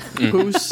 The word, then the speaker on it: Sommerhus: So we got to go through the Sommerhus. Is that how you say it? – Hoos.